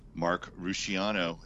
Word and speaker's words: Rusciano: And Mark Ruciano, is that how you pronounce it, by the way? – Yep, we're good friends, by the way.